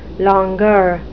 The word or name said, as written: longueur